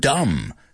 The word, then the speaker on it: dumb: Like the word dumb, tonto. That word, that B, we don't pronounce it.